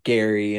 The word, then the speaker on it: Gerry: His name being Gary and it's spelled Gerry.